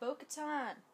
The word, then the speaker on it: Bo-Katan: Metal Mando said Bo-Katan. – Hey, good job for pronouncing that right.